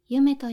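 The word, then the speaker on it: 結女: ゆめです。結ぶという字に女と書いて、ゆめと読みます。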